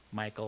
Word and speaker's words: Michal: Well, rather than trying to do that every time we come to this, I probably throughout this will tend to use the more common pronunciation of Michal. But if you understand, there's a couple different ways of saying this. I'm aware of that. But I'll probably go with the more common name here, Michal or Michal.